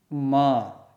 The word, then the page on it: m: Sanskrit